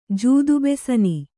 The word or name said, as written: ಜೂದುಬೆಸನಿ